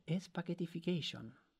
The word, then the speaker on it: spaghettification: Spaghetti, Spaghettification. – face, spaghetti face. say, say it again. Spaghettification. – Spaghettification.